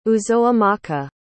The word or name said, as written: uzoamaka